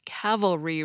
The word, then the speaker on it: cavalry: About the only way I can seem to get myself to pronounce cavalry right is to think of the word cavalier first and sort of set my brain on the cava part.